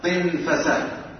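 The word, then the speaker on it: طِنْفَسة: طنفسة. وهذه الكلمة أعني طنفسة معناها البساط، معناه البساط، والطنافس البسط، والصحيح أن تنطق بكسر الطاء وفتح الفاء طنفسة، وهذا هو الأفصح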